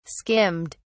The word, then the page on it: skimmed: British English